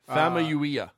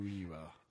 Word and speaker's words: Famuyiwa: Rick Fumiyawa, I know I'm butchering his last name. – Famuyiwa. – Yeah, that guy, is leaving the project. I mean, it's disconcerting. It's disconcerting. – Famuyiwa.